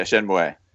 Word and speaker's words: Shenmue: How do you pronounce it? Shenmue? – Yeah, Shenmue.